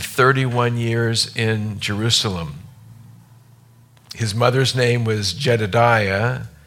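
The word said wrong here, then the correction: Jedidah: Did I say that right? Jedida, the daughter of Adiah of Bozkath.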